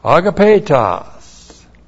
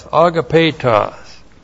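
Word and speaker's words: Agapetos (ἀγαπητός): Agapetos. Looks like that in the Greek, A-G-A-P, long E-T-O-S. Agapetos.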